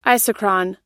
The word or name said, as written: isochron